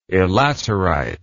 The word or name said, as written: elaterite